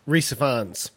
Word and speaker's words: Rhys Ifans: Like, Rice, how do you say his name? – Who played Earl of Oxford? – Rhys Ifans.